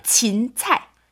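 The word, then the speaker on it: 진찰 (芹菜): 네, 샐러리 발음은 네. 진찰. – 진찰? – 네, 찰은 우리 일반 얘기하는 야채. – 네네. – 찰. – 진은? – 그리고 앞에 진 붙이면 됩니다. 진찰.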